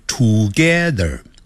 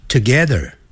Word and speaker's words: together: I live together 不要念成 together，要念 together together。那个 T-O-G-E-T-H-E-R 有个 R 结尾，所以在美式的发音呢要卷舌的。